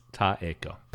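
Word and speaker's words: Taeko: Ichikawa reserves actual material hardship for Takeiko. – You've lost it. You got worse. Not better. – I did it worse. – Taiko. Yeah. Taiko. All vowels are pronounced. Taiko.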